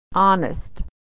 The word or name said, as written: honest